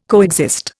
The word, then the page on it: coexist: Indian